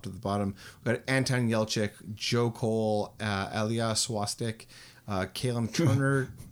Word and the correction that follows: Shawkat: Ilya Shawkat? – Sorry, did I say that wrong?